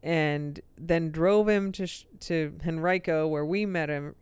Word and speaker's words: Henrico: So we finally got it all set up that we were going to meet her in Henrico, which is kind of in between. Did I say that right? – Yeah. – Henrico.